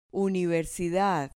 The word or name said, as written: universidad